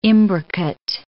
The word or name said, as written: imbricate